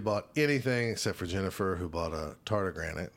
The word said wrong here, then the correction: tardigrade: Am I saying that right? – Tardigrade. – What did I say, tardigranate? – Yeah.